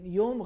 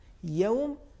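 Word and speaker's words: يوم: جیسے مثال کے طور پر اگر ہم کہیں گے مالک یوم الدین, یوم غلط ہو جائے گا, چونکہ حرف یا پر زبر ہے پھر واو ساکن ہے. یوم صحیح تلفظ ہے نرمی کے ساتھ.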